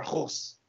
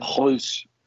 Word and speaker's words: Kroos: Or Kroos. Using the German pronunciation. – Kroos.